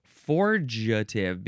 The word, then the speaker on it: forgetive: I think I said forgative, because forget has that hard G, but this is forgative, because it's from the word forge, of course.